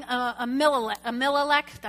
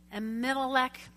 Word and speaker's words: Ahimelech: King Ahimelech. I said that wrong. Ahimelech.